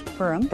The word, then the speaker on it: Purim: Purim.